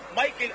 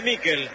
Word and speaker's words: Miquel: ¿Cómo le decimos? ¿Michael o Miquel? Nicolás Álvarez, usted que es especialista en nombre. Se escribe Miquel. ¿Cómo le digo? – Decile Miquel. – Entonces le digo a Miquel, usted me enseña y yo aprendo.